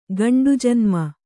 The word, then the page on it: ಗಂಡುಜನ್ಮ: kannada